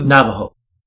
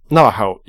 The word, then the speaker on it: Navajo: They spoke about Navajo skinwalkers. And I listened to... – Navajo. – Navajo, yes, sorry.